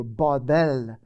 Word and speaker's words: Babel: That's how they pronounce it in the Hebrew, Babel.